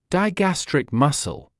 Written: digastric musсle

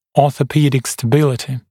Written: orthopedic stability

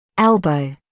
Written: elbow

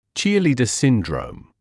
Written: cheerleader syndrome

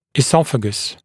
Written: esophagus

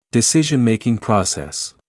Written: decision-making process

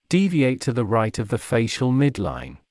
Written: deviate to the right of the facial midline

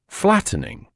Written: flattening

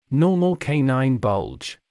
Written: normal canine bulge

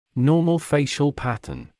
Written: normal facial pattern

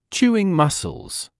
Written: chewing muscles

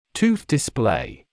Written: tooth display

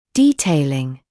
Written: detailing

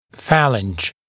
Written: phalange

